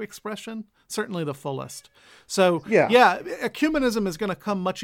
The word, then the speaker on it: ecumenism: Ecumenism is usually the word.